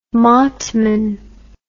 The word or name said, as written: matman